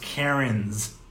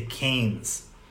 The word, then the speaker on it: Cairns: And if you pronounce it the way it's spelled, it's pronounced Cairns, but they over there they say Cairns.